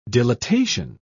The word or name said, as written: dilatation